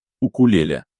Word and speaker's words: укулеле: Укулеле произносится как укулеле.